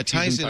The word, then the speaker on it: Tizen: There's also these alternative operating systems like Tizen, if I'm saying that right. – Yeah, Tizen.